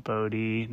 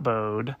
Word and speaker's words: Bode: the brand is called bodie not bode um, even though it's spelled B O D E.